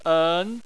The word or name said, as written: en2